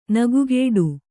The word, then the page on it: ನಗೆಗೇಡಿ: nagegēḍi; kannada